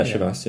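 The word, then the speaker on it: Chivas: Am I pronouncing that right? – Yeah, Shivas, yeah.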